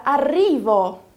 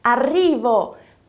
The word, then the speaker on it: arrivo: Mas agora olha para a palavra arrivo, que significa tô chegando. Ela é escrita com dois R's, você viu? Sempre que tiverem dois R's assim juntinhos, aí a pronúncia vai ser bem vibrante. Arrivo.